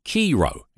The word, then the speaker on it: Chi Rho: Now, this is an occult sign that's a few thousand years old, and it's spelt, it's called Chi Rho. It's spelt C H I R H O.